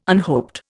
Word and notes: unhoped: Indian

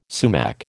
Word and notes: sumac: American English

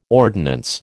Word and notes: ordnance: American English

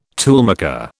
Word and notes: toolmaker: British English

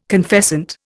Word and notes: confessant: Indian

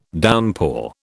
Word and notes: downpour: British English

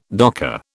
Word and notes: docker: British English

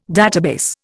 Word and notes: database: Indian

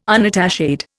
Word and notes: unattached: Indian

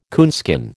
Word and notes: coonskin: American English

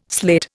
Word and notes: slate: Indian

